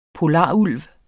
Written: polarulv

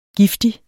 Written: giftig

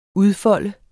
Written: udfolde

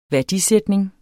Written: værdisætning

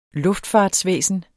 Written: luftfart

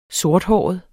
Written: sorthåret

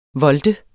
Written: volte

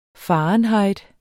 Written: fahrenheit

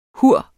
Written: HUR

